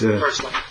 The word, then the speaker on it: purslane: Maybe some of you are familiar with, and it sounds like porcelain, but it's spelled a little bit differently. I think it's purslane. That's purslane.